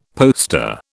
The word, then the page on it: poster: British English